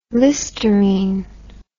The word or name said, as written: listerine